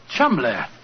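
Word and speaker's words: Cholmondeley: Being an American, he had no reason to know that the name Chumley is in no way pronounced the way it is spelt. – But you have never thought of that. Chumley. That name spelt C-H-O-L, Chow, M-O-N, Mon, D-E-Dur, L-E-Y.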